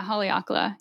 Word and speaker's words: Haleakala: Haleakala? – Yeah, Haleakala.